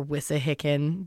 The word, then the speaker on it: Wissahickon: Like the Schuylkill or Conshohocken or Wissahickon. Those I'm pretty sure I'm saying right.